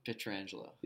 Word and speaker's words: Pietrangelo: Petrangelo.